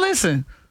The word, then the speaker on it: listen: Silent and listen? – T. Listen. You don't hit no T in listen.